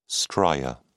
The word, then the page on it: stria: British English